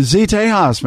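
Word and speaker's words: Z'Tejas: Z-Tejas? – Z-Tejas, man.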